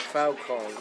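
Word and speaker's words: Falcón: Falcon? As in the bird? – Falcon, yes. As in, it's spelled as in the bird, but Spanish.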